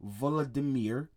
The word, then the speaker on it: Volodymyr: President Volodymyr, that's a weird name.